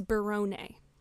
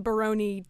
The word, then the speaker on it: Berrone: And apparently, it is Berrone, which is a lot better than Baroni to rhyme with macaroni.